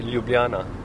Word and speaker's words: Ljubljana: What's the right way to pronounce the city? – No, the right pronunciation would be Ljubljana. – Ljubljana. – Ljubljana. – Ljubljana. – No, you're saying the last J is.